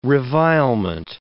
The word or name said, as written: revilement